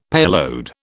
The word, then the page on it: payload: British English